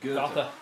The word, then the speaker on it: Goethe: Is it Goethe? Goethe? – Goethe.